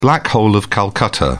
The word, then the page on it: black hole of calcutta: British English